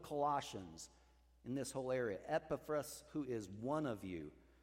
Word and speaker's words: Epaphras: So Epaphras, I said it wrong. It's more Epaphras.